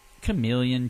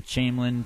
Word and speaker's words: chameleon: Chameleon, Chamberlain, potato, potato. – No, it's not potato, potato. It's a chameleon.